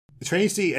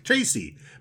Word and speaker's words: Traysi: Tracy, and you just now said her name out loud, and it's like, Tracy, but it's spelled T-R-A-Y-S-I. – Yeah. – I never realized that it's just Tracy.